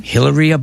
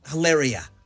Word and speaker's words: Hilaria: I don't think you're saying her name right. It's a different name because they were making fun of her. – Hilaria. Is that how you say it? – I think so.